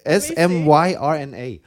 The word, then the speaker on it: Smyrna: S M Y R N A. Smyrna. I would say that's Smyrna, Georgia.